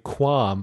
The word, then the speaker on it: Quam: On IRC, I went by Quam, but not spelled like I have no qualms about it, spelled Q-U-A-M.